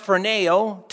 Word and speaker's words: phroneo: Well, if you look at the Greek word, and that the Greek word phroneo, it's pronounced phroneo, to think.